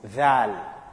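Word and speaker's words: dhal (ذال): The second letter which we pronounce, or when we pronounce, the tongue comes out is dhal. Is the one we're talking about now. Dal, dhal, dhal. The tongue will also, must come out in that pronunciation.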